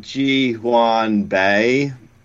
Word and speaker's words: Ji-Hwan Bae: Ji-Hwan Bae. Sure, I'm saying that right. – I think you did, actually.